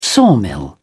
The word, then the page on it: sawmill: British English